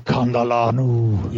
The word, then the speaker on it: Kandalanu: Kandalanu. – Yeah, that guy, Kandalanu.